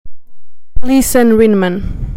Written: Lisen Rinman

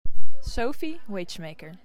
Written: Sophie Wagemaker

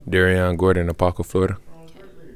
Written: Dariyon Gordon

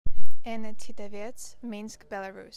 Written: Anna Tsitavets

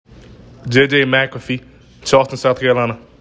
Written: Jjay Mcafee

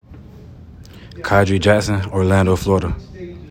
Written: Khadry Jackson

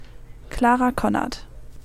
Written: Clara Connert

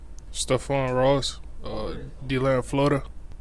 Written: Stephone Ross

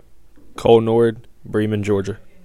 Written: Cole Norred

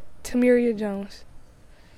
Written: Tamiria Jones

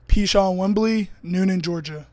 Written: Pichon Wimbley

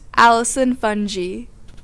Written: Allison Funge

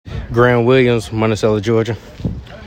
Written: Graeme Williams

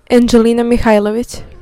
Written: Angelina Mihajlovic

